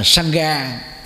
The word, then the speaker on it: Sangha: Còn Sangha thì người Ấn Độ đọc là gì? Sang, chữ A đó không có đọc, A ở cuối từ là không có đọc.